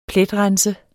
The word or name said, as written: pletrense